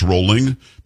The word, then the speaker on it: Rowling: Having said that, a lot of people think it's J.K. Rowling. It's not, it's Rowling because she says Rowling, but I'm with you. – But it looks spelled like Rowling. Rowling, right? – Yeah. It's spelled R-O-W as a row.